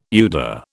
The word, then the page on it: UDA: British English